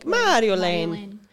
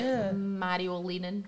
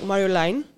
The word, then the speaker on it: Marjolein: Like, oh. I always say to fuck, like, it's like Mario, like Super Mario. – Mario. – Walking down the lane, like, Marjolein.